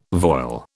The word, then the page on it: voile: British English